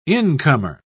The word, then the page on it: incomer: British English